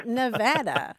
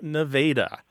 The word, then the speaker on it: Nevada: So they want the word to get out that it is Nevada. – My story is even worse. I grew up in Missouri, and there's a town that's spelled the same, but it is pronounced Nevada. So for me, the state was Nevada for a very long time because that's how you pronounce the town in Missouri.